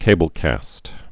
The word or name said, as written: cablecast's